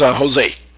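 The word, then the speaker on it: José: The name is pronounced Jose. And it really has, it is an accent in the E, but it's, most people call me Jose.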